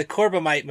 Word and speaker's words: Corbomite: And then Michael Dunn, who played Alexander, he's also considered to play Baylock in the Corbomite. – I never say it correctly. – Somebody else say it. – Okay, the Corbomite maneuver. – There you go.